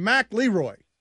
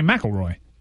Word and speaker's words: McIlroy: You say McElroy.